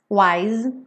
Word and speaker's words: wise: Wise, não pronuncio é, wise.